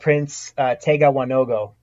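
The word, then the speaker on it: Prince Tega Wanogho: We are not talking about Prince Tegawanogo. – We are not talking about Prince Tegawanogo.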